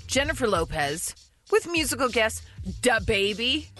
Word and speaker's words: DaBaby: DaBaby. – That was pretty good. – I mean, she put a lot less emphasis on duh.